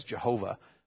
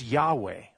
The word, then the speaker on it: Yahweh: Now, The phrase there, I am who I am, is very closely related to the name that we use, Yahweh, which we often pronounce as Jehovah, which is a English, anyway, it's gone through German, and we pronounce it in our English way as Jehovah. But it's Yahweh, okay?